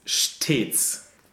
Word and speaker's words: stets: But in the fourth line of the second verse, we have the word S-T-E-T-S. – Stets. – Which is a closed E sound, which is the closed lowercase E. – Stets.